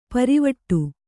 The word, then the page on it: ಪಾರಿವಟ್ಟೆ: Kannada